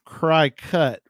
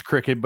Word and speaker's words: Cricut: C-R-I-C-U-T. We call it cricket. Yeah, I think it's pronounced cricket, but it's not spelled cricket.